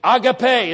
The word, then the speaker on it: agape: Agape is the right way to pronounce it as I understand.